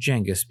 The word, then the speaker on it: Genghis: If you watched the television show Marco Polo from a few years back, they favored Genghis because that is closer to the original Mongol pronunciation. His name, or rather his title, was spelled with a G because the English learned the name from the Italians, whose G is usually pronounced like a J when it appears at the beginning of words.